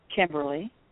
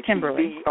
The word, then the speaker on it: Kimberly: Kimberly. – Chevrolet. – Kimberly. – Spell that for me, please. – K-I-M-B-E-R-L-Y.